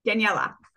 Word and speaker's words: Daniella: I hope I pronounced that correctly. – Daniella. – Daniella. Oh my God.